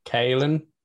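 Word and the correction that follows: Caolan: Colin. Yeah. – Colin Lavery. – That's very good, yeah.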